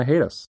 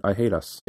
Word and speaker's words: hiatus: And I pronounce it I hate us. – Yeah. – Instead of hiatus.